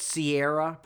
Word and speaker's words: Ciara: Isn't that Sierra Bravo? C-I-A-R-A? – Well, some people pronounce it Sierra, yeah.